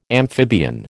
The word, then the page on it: amphibian: American English